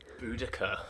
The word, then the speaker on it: Boudica: Boudica.